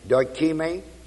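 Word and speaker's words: dokimē: D-O-K-I-M-I-O-N. Remember experience I gave you a little while ago as dokime, D-O-K-I-M, long E. This word, trying, is the word D-O-K-I-M-I-O-N.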